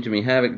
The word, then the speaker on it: Jimmy Havoc: Is that his name, Jimmy Havoc? Did I say that right? – Yep, you got it.